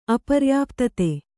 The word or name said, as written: ಅಪರ್ಯಾಪ್ತತೆ